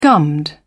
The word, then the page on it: gummed: British English